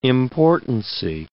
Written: importancy

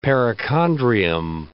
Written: perichondrium

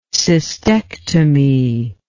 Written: cystectomy

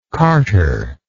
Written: carterite

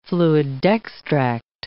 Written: fluidextract

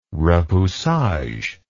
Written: repoussage

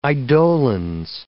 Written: eidolons

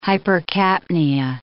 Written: hypercapnia

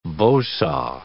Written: bow saw

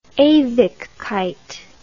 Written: ewigkeit

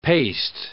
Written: paste

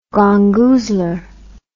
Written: gongoozler

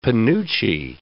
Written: penuche

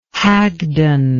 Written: hagdon

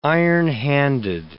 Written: ironhanded

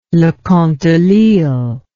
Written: leconte de lisle

